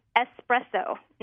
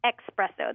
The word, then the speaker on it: espresso: It's espresso, not expresso. There's no X.